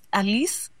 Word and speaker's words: ALICE: Should be LICE, not ALICE. Thoughts, team? – I think because he pronounces it as ALICE, I think it's correct.